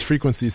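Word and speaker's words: Freequense: my producer name is Frequency, spelled F R E E Q U E N S E E.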